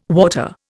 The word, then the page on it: water: Indian